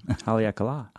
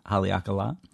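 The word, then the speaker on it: Haleakalā: On Haleakala, Hawaii. I hope I pronounced that right. – Haleakala. – Haleakala. Yeah.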